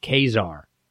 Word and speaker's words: Caesar: Yes, Julius Caesar was part of the Roman Empire, and actually it's pronounced Kazar.